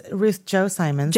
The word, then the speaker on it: Ruth Chou Simons: Do I pronounce it Ruth Chou Simmons? – It's Ruth Chou Simmons. – There's no way you would have known that. But yeah, it's Mandarin.